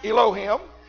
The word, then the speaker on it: Elohim: Now the word gods here is the same word that is used to describe one of the names of God, Elohim, pronounced Elohim.